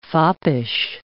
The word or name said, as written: foppish